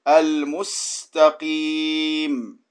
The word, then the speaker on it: المستقيم: Quand tu prononces sin, il faut qu'on entende un sifflement. Al-mustaqim. Al-mustaqim. – Al-mustaqim. – Non, tu dis al-mus. Ça, c'est sad. Sin, c'est, elle est faible.